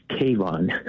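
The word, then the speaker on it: Kavon: No, it's K-A-V-O-N, like Avon with a K.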